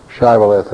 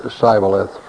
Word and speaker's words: Shibboleth: If he said, "Nay," they saith unto him, "Say now, Shibboleth," and he said, "Sibboleth," for he could not frame to pronounce it right.